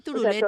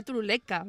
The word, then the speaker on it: turuleca: Que yo decía turuleta, o sea, por cierto. Y era turuleca, ¿verdad? – Yo digo turuleta porque lo digo mal, pero la correcta es turuleca.